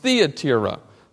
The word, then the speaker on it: Thyatira: I would always call it Thyatira. Uh, then I heard my, my Bible reading uh, called it Thyatira, and then I heard someone say it's actually pronounced Theatira.